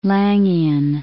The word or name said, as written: Laingian